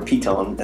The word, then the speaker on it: Python: Python, or Piton, as it is pronounced in Dutch,